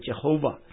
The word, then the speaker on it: Jehovah: 所以当他们想到上帝既然他自己启示 YHWH，那么他们就把 Adonai 里面的那个 AOA 这三个元音呢就插入到 YHWH 里面，所以呢这样放进去以后呢，这个词就变得可以发音。那么这个发音呢就是其实呢是中文的耶和华是非常接近这个发音，那英文呢就变成 Jehovah，实际上呢就是 YHWH 的这个再把 Adonai 这个元音放到里面去。